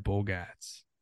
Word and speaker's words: Bogaerts: Yeah, Bogaerts.